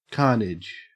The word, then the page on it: carnage: Australian English